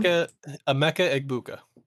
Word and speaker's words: Egbuka: Emeka Egbuka.